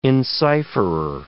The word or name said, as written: encipherer